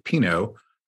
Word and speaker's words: Pino: So we have a guest today who is Dominic Pino of National Review.